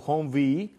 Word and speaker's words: Humvee: Esto, señores, termina siendo Humvee, pero realmente sería HMMWV.